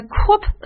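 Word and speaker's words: corps: 那么专门是指参与同一工作的人员，我们叫做 a corp of engineers. 首先要提醒各位，再次提醒各位，那就是 corp 的发音，结尾的 s 呢不发音。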